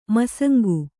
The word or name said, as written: ಮಾಸಂಕೆ